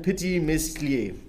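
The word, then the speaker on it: Petit Meslier: Da kommen noch hinzu der Pinot Gris, der Pinot Blanc, Arban und bei der letzten kann ich es mir nicht aussprechen, Petit Mistlier. Alles richtig ausgesprochen.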